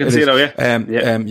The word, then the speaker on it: Cancelo: Is it Cancelo is his name? I feel like I'm saying his name wrong. – Yeah, right, Cancelo,